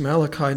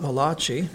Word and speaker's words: Malachi: The proper pronunciation is Malachi, not Malachi. I remember a friend in college who was joking and saying, well, it's not Malachi, the Italian prophet, you know. If he was Italian, I suppose that's how you would pronounce it.